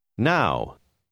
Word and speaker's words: now: Now. Now. – La combinaison OW a une autre prononciation que celle que vous avez déjà apprise.